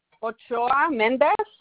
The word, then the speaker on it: Ochoa Mendez: Ochoa Mendez.